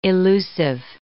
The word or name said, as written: illusive